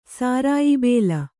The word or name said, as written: ಸಾರಾಯಿಬೇಲ